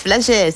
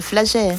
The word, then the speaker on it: Flagey: place Flagey, ça s'appelle le Flagey. – Flagey, oui. Toi, tu as bien prononcé.